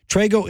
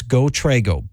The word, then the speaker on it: Trego: Trago is spelled T-R-E-G-O. It's GoTrago.